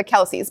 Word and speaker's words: Kelce: Now, for a really long time, everyone in their lives, including like their grandfather, people that they work with, everyone just automatically says Kelce. So essentially, the Kelce family has even just started calling themselves the Kelce's. But technically speaking, it's pronounced Kelce.